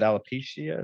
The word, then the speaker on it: alopecia: Like I didn't know she had alopecia. Is that how you say it? – Yeah.